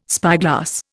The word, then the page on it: spyglass: Indian